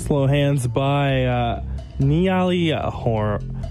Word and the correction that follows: Niall Horan: I hope I'm saying it right. Oh, it's Niall Horan. Sorry.